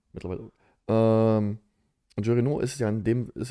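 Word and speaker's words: Jean Reno: Wie spricht man den richtig aus, den dritten da? – Äh, Jean Renault.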